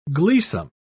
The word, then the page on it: gleesome: British English